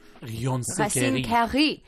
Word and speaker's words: Racine Carrée: Racine Carrée. – Where is it? – Racine Carrée. – That's how it's going to be. – Racine Carrée.